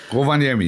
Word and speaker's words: Rovaniemi: Rovaniemi, ja.